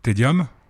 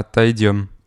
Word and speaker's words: Taedium: Alors comment tu prononces ça Lequel Tedium. – Ah, taedium. – Taedium. Moi, j'ai cru que c'était Tédéum, euh, comme dans la musique classique, et puis c'est pas ça.